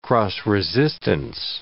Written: cross-resistance

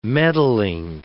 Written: meddling

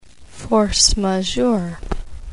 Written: force majeure

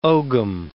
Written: ogham

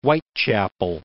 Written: Whitechapel

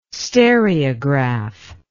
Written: stereograph

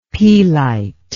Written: pelite

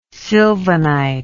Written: sylvanite

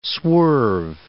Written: swerve

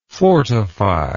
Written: fortifyingly

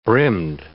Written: brimmed

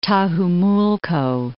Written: Tajumulco

